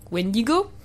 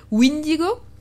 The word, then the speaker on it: Wendigo: On trouve comme prononciation et surnom principalement donc Wendigo, donc pour, vous l'auriez compris, Windigo, une petite variante, et Witico.